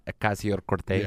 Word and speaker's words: Ocasio-Cortez: Said it wrong. – How did it? Why? – You didn't say it correctly. – How? What is it? – You're supposed to say it like you normally say it. – Alexandra. – With a Hispanic accent. – Alexandria Ocasio-Cortez. – Yes, that's correct.